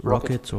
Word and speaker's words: rkt: One of them is called RKT, or however Rocket. You pronounce it, Rocket.